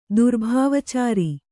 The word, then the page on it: ದುರ್ಭಾವಚಾರಿ: kannada